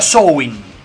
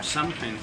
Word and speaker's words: Samhain: Se pronunciaba Samhain. Al parecer esa es la idea. – Samhain. – Se pronunciaba Samhain. Así de raro.